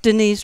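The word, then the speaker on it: Denyse: You can look up Denise Drummond-Dunn.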